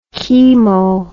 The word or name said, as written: chemo